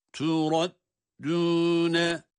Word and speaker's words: تُرَدُّونَ: ثُمَّ تُرَدُّونَ dal harfini burada ince okumaya azami özen göstereceğiz. Kendisinden önce ra harfi var, kalın okunuyor olması dal harfine aslı sirayet etmez. تُرَدُّونَ bakınız dal ince.